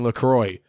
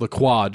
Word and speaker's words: LaCroix: It's not fucking LaCroix, white fucking soccer moms and Kroger. It's LaCroix. Just because you're not French doesn't mean you can change the fucking pronunciation.